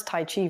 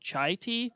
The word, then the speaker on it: Tai Chi: By the way, you pronounce Tai Chi very well. – Oh, thank you. Earlier today, I told someone I was doing a podcast and I accidentally said chai tea.